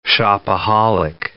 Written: shopaholic

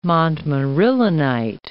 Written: montmorillonite

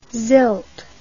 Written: sylt

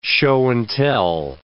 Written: show-and-tell

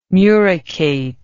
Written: muricate

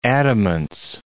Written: adamance

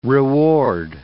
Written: reward

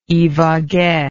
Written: ibague

